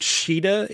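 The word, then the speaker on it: Caeda: I don't remember Kane or I guess it's pronounced Shida in the game. Spelled like Kaeda.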